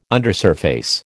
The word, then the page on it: undersurface: American English